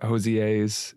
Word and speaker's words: Hozier: Yeah, so I got a lot of, you get a lot of Hoziers, you get a lot of Hoziers, you know. You go to France, it is originally a French name. So over there, it's Hozier. So, That's the French pronunciation.